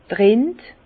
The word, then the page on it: d' Rind: Alsatian; Bas-Rhin; Herrlisheim